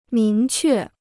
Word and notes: 明确: míng què

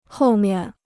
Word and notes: 后面: hòu mian

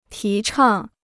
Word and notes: 提倡: tí chàng

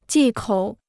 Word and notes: 忌口: jì kǒu

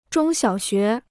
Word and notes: 中小学: zhōng xiǎo xué

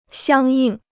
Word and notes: 相应: xiāng yìng

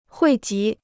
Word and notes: 汇集: huì jí

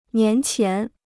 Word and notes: 年前: nián qián

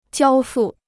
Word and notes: 交付: jiāo fù

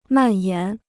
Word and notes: 蔓延: màn yán

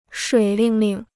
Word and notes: 水灵灵: shuǐ líng líng

